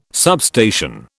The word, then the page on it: substation: British English